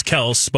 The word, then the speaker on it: Kelce: Yeah, he joined a podcast last year, a couple years ago, I guess now, 2021, and somebody said, this is Travis Kelce. By the way, that's how you're pronouncing it, right? And he's like, no, everyone's been pronouncing my name so wrong all the time. It's Kelce, but I just live with it at this point.